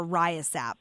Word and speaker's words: RYASAP: I think is how you can pronounce it. Do you say R-Y-A-S-A-P or RYASAP? – RYASAP is the best way to say it.